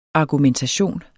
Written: argumentation

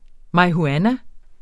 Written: marihuana

